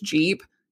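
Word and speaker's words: Gepp: There's one place, it's G-E-P-P. Looks like Geb, but it's Jeep.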